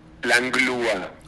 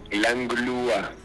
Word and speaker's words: Langlois: Langlois, se escribe tu apellido, pero se pronuncia Langua. – Langlua, con una L entre la G y la U. Langlua.